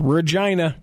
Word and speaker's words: Regina: Sure, but in Canada, just like it's Tooele, not Thule, or, you know, any of those things here, it's pronounced Regina.